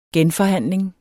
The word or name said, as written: genforhandling